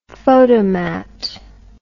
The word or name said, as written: photomat